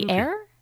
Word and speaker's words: luthier: But I just wanted to give him a shout out because he's kind of becoming the unofficial, how do you say it, luthier? Luthier? – That's correct. – Luthier? Yeah, luthier. Okay.